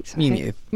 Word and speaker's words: Miu Miu: Miu Miu.